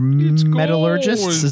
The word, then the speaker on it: metallurgists: And it's basically crafted from 18 karat gold that they're metallurgists. Is that how you say it? – That is indeed how you say that.